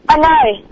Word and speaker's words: Anay: What's your name again? – Anay. – How do you spell it? – A-N-A-Y. – Anay, all right.